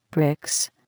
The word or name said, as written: bricks